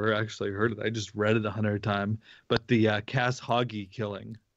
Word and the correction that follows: Khashoggi: Oh, Jamal Khashoggi, the guy who was killed, the journalist in Turkey.